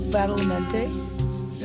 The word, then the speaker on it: Badalamenti: Angelo Badalamenti.